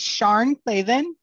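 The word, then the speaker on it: Sian Clavin: It's Sharn Clavin, but it's spelled S-I-A-N Clavin. – Ah! Okay. C-L-A-V-E-N or I-N? – E-N.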